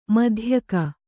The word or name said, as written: ಮಧ್ಯಗ